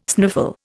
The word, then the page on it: sniffle: Indian